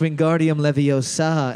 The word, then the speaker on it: Wingardium Leviosa: It's pronounced Wingardium Leviosa and Yeah.